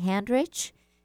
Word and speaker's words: Handrich: And of course, you can always write to me at my Facebook Messenger page, and that is Debbie Handrich. It's spelled exactly as it sounds. Last name is H-A-N-D-R-I-C-H.